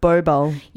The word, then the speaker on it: Bobal: So do you think you can pronounce that? B-O... – Well, I'm going to go with Bobal. – Yeah, that's how I was thinking.